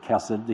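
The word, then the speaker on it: chesed (חסד): We pronounce it chesed. The Hebrews wouldn't. Jews wouldn't. And anglicized, it's C-H-E-S-E-D, but there's no vowels in Hebrew.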